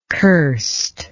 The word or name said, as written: curst